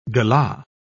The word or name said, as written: galah